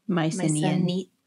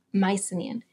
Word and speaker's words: Mycenaean: Mycenaean. – Mycenaean.